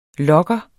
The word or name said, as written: lokker